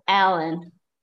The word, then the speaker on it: Helen: Because in Portuguese, you don't really pronounce any H sounds if it's at the beginning of a word, right? So like if you have a girl with a name spelled H-E-L-E-N, in Portuguese it's Ellen, right? – Ellen, yes.